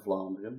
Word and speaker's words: Vlaanderen: I'm from Vlaanderen, yes.